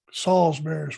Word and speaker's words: Salisbury: Is it Salisbury, Maryland, or Salisbury? – Salisbury is what I say.